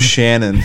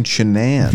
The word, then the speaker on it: Shanann: Yeah, I thought it was Shannon for the first like probably 99% of the documentary, and then like towards the end, I was like, oh, they're saying Shanann. Yeah, like, that's why it's spelled weird.